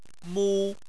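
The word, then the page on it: mu1: tone 1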